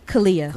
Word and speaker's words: Caleya: Yes, you can find me on Instagram and Twitter at Kalia Black, and it's spelled C-A-L-E-Y-A Black. On Facebook, is it Kalia or Kalia? – Kalia.